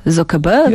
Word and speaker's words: Zuckerberg: Właśnie, jak się wymawia jego nazwisko? Tak jak powiedziałeś? Zuckerberg? – Yes. Well, what do you want to say? – Nie wiem, no, ludzie mówią bardzo różnie. – Yes, Mark Zuckerberg.